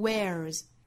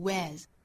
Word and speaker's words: wears: 英音和美音差别比较大的词呢就是 wear 这个词，美音读 wears，英音呢是双元音读 wears。